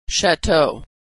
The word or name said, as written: chateau